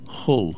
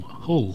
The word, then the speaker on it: Hull: This is the band from Hull, who, although they're from Hull. – No, it's Hull. – Hull. – Hull. – Hull. Okay. – Yes. – Aye. – Aye. Hull.